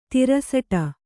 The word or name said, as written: ತಿರಸಟ